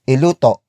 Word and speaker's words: iluto: For example, the words iluto and lutuin. Iluto, lutuin. Iluto, lutuin. Iluto is spelled as I-L-U-T-O, while lutuin is spelled as L-U-T-U-I-N.